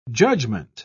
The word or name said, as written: judgment